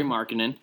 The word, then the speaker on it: Markkanen: Lauri Markkanen.